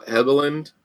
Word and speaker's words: Helgeland: Helgeland.